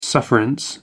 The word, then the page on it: sufferance: American English